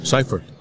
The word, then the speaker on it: Seifert: Seifert.